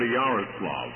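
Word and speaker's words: Yaroslavl: How did you come to go to Yaroslav, if I said it right? – He did fairly well. I think he qualifies to come and visit us since he knows how to pronounce it.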